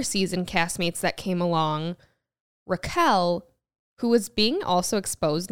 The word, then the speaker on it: Raquel: I heard she was always called Rachel and then for the show decided that her name would now be pronounced Raquel.